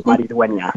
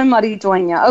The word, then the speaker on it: Maridueña: It's a little difficult because of the X, but it's pronounced Xolo Maridueña. – Xolo Maridueña.